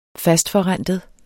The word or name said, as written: fastforrentet